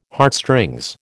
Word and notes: Heartstrings: American English

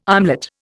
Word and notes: armlet: Indian

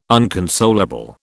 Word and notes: unconsolable: British English

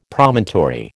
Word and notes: Promontory: American English

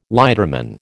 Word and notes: lighterman: American English

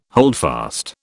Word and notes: holdfast: British English

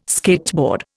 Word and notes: skateboard: Indian

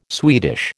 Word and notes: Swedish: American English

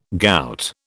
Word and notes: gout: British English

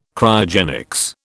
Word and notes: Cryogenics: British English

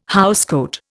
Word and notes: housecoat: Indian

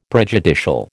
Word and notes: prejudicial: American English

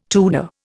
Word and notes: Tudor: Indian

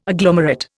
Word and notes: agglomerate: Indian

